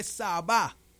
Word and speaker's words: Sabá: No es la reina, dice, es la reina de Sabá, porque tiene acento en la A.